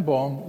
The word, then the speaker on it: Boom: One of them is Caspar Ten Boom. Caspar Ten Boom was the father of Corrie Ten Boom. By the way, they were from the Netherlands, and we pronounce it boom, but they pronounced it boom.